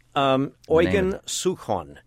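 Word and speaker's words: Eugen Suchoň: Eugen Suchon.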